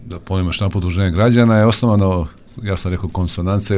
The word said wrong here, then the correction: Konsonanca: ili možda nije dobar izgovor. – Konsonanca, skladnost.